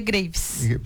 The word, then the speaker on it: Graves: Se escreve Graves. Uhum. Mas não é porque a doença é grave, também é um problema que a pessoa fala, mas se pronuncia Graves.